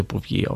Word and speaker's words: Bouvier: Wenn ich es falsch ausspreche, bitte korrigiert mich, ich würde das gerne dann in der nächsten Folge erwähnen, aber wie ich herausgefunden habe, würde man das ganze Bouvier aussprechen. Es ist auch wieder eine Kreuzung, und zwar aus dem gelben Muscateller und dem Weißburgunder.